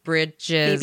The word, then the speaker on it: Bridgers: Bridgers. Lawrence. Okay. I didn't know if the R was silent or not. – Why would the R be? – Like, you know how, because in the British accent, the R is silent sometimes.